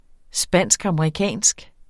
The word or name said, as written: spansk-amerikansk